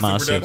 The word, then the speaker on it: Masue: Masu Productions. – Just Masu Productions. – That's right. – M-A-S-U-E.